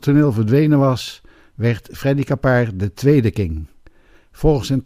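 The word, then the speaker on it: Keppard: Capard op zijn Frans uitgesproken.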